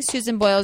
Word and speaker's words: Susan Boyle: Susan Boyle is writing an autobiography chronicling her rise to fame. What? Did I say it wrong? – No, you said it right.